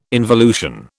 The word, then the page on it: involution: British English